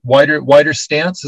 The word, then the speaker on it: Widerstand: You've got the consulting group Wider Stance. Is that right? – We use the German word Widerstand, pronounce it in English, but it's German word for resistance.